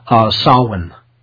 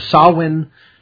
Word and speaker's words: Samhain: It's called Samhain. Okay, so Samhain, that's spelled S-A-M-H-A-I-N, or Halloween, or All Hallows Eve, is October 31st.